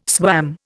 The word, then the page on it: swam: Indian